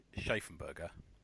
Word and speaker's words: Schaffenberger: The inker in Superman 423 was George Perez, and the inker in 583 was Kurt Schaffenberger. I believe that's how you say it. Is that right? Yeah. – Yep.